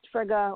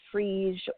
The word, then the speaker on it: Frigga: And Frigga is one of those really complicated ones. And I've heard it pronounced Frigga or Frigg or even Frige or Frigia. And I think, again, that depends on location.